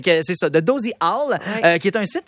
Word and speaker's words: owl: Owl, je me souviens jamais comment on le prononce O-W-L? En anglais. – Ouais. Owl.